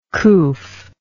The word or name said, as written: coof